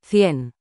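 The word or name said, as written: cien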